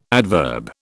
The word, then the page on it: adverb: British English